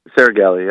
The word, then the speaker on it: Saragelli: Yes, I would say that Amelie Anderson and Mira, and she pronounces it Sorelli? – Saragelli, yeah. – Saragelli. It is the G. I thought the G belonged, but the other guys keep dropping it.